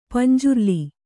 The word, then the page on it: ಪಂಜುರ್ಲಿ: panjurli